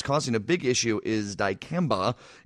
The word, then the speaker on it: dicamba: Dicamba? Is that how they say that? – Dicamba.